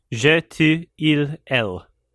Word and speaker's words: Je tu il elle: Also, for the Jeanne Dielman lovers out there, do yourself a favor and watch, and I'm going to butcher this. Je tu il elle. Oh, Is ah. that right? – Yeah. – Je tu il elle.